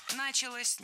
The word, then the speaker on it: началось: И вот именно в тот момент в Голландии, если я правильно понимаю, началось развитие вот именно... – Началось неверно. Вот здесь уже ударным становится окончание. Началось. Дело в том, что возвратная часть «се» во многих словах как бы притягивает ударение к себе.